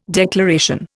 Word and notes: declaration: Indian

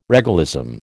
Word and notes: regalism: American English

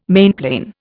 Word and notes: mainplane: Indian

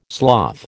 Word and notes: sloth: American English

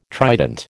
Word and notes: trident: American English

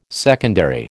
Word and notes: secondary: American English

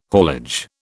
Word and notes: haulage: British English

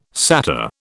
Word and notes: satyr: British English

